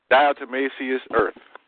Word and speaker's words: diatomaceous earth: Diatomaceous earth. I may be pronouncing it wrong. It's D-I-A. – No, you're pronouncing it right. That's right. – D-I-A-T-O-M-A-C-E-O-U-S.